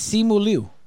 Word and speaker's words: Simu Liu: It's Simu Liu.